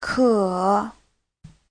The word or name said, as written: ke3